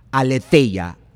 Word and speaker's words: aletheia: La palabra verdad como un sustantivo se escribe aletheia, A L E T H E I A, aletheia.